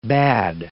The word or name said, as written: bad